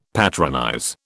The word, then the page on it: patronize: British English